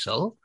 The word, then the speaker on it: Saul: So I say like Paul, but with an S, but that doesn't always help. – Right. No, I'm confused now. So is it Saul now?